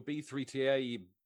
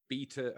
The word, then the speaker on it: B3TA: So, you know, with your B3TA, beta, however you want, how do you pronounce it? – It's beta. – It's beta. Okay, right. So I've been calling it B3TA all these years.